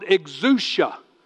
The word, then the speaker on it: exousia: Okay, number two, it's called exousia, and it's spelled E-X-O-U-S-I-A, exousia power.